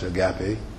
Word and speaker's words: agape: Love, the love that is spoken of here is the word that comes from the Greek word agape, often pronounced agape, which is which is to be distinguished from other kinds of love.